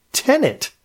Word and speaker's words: Tenet: I'm just hoping it helps people learn how to pronounce the word Tenet. That would be awesome. – Did I pronounce it wrong? – No, I don't believe you did. Most people put a second N in it, as if it's the person renting your basement apartment.